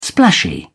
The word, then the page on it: splashy: British English